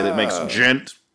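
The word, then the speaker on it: Djent: Is that how it's Gent. Pronounced? – Yeah, yeah. Gent.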